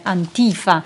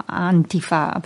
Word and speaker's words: Antifa: questo vertice Antifa, pronunciandolo all'americana, o Antifa pronunciandolo alla tedesca, dalla quale viene, dal tedesco viene appunto questa espressione, che sta appunto per antifascista, ma in un senso molto diverso da quello che intenderemmo noi.